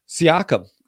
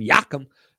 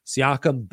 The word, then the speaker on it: Siakam: And then Siakam. I love how Shaq pronounces Siakam's name, Yakum, and over 3.5 assists.